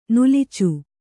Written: ನುಲಿಚು

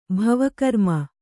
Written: ಭವಕರ್ಮ

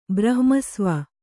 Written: ಬ್ರಹ್ಮಸ್ವ

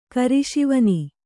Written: ಕಿರುಶಿವನಿ

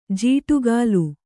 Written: ಜೋಡಗಲ್

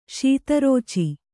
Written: ಶೀತರುಚಿ

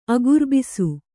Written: ಅಗುರ್ಬಿಸು

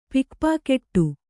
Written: ಪಿಕ್‍ಪಾಕೆಟ್ಟು

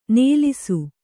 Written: ನಲಿಸು

